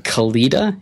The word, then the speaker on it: Kalita: Kalita. It's K-A-L-I-T-A.